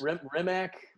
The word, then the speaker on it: Rimac: So, for those of you who aren't super familiar with the world of electric cars, over in Croatia, there's a company called Rimac or something, because I always... – Rimac.